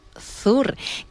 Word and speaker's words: Sur: Viajamos hacia el sur de la península para escuchar a un grupo llamado Sur, que se pronuncia en Andalucía, vamos, lo dirían Sur, precisamente.